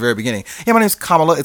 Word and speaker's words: Kamala: I was like, it's got to be Kamala. It can't be Kamala because it sounds like comma with la at the end.